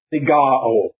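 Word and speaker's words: sigaō: Now when we come on to this next word, the word that is a part of our text, it's pronounced something like cigar, if that's any help to you. Cigar-o. I don't know what the O is doing on the end, but anyway, that's the way it is in the Greek.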